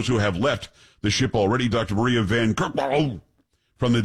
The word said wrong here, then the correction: Van Kerkhove: It's Van Kerkhove. I'm pretty sure that's how you pronounce it.